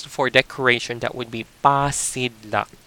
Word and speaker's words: pasidlak: We call that pasidlak.